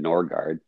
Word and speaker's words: Norgard: and as well as when we talk about like ancestral stuff, you asked how to pronounce my name, Norgard. It's definitely Danish and Norse.